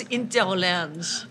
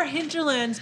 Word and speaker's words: Hinterlands: H, I don't know how to pronounce the H. – Except if I'm saying airport. Yeah. – We call the bar Hinterlands because there's a little bit of like, I'm just going to keep talking while you guys giggle.